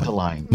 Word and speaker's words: Mundelein: Mundelein, is that the way you pronounce it? – Mundelein.